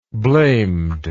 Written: blamed

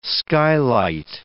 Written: skylight

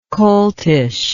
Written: coltishness

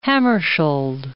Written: Hammarskjold

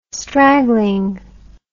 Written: straggling

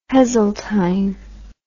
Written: Heseltine

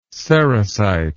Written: sericite

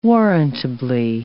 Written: warrantably

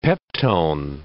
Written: peptone

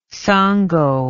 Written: Sango

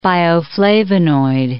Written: bioflavonoid

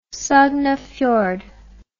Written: Sognefjord

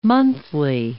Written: monthly

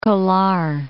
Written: Kolar Gold Fields

